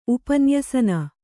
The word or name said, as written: ಉಪನ್ಯಸನ